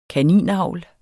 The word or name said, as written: kaninavl